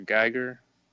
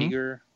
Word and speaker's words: Giger: How you say his name? H.R. Giger or Geiger? Mm-hmm. Giger.